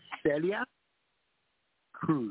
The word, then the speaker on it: Celia Cruz: How do you say it yes. – in Spanish? – Celia Cruz.